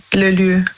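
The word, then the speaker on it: lieu: Also Lü, das schreibt man also L-I-E und dann U. Lü.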